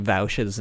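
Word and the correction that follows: Vaush: name wrong. – Honestly, keep fucking it up. – It's supposed to be Vosh.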